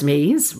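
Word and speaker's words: SME: Charmingly pronounced SMEs, rhymes with cheese, these are employees identified as having functional expertise.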